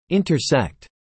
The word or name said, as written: intersect